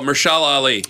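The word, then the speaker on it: Mahershala Ali: Meshumbapolitan Ali. – Yep. – Oh, Mershala Ali.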